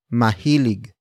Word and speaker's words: mahilig: Mahilig, akong, mamili, ng, sitsirya. Mahilig, akong, mamili, ng, sitsirya. The first word is mahilig. Mahilig. Mahilig. Mahilig is spelled as M-A-H-I-L-I-G.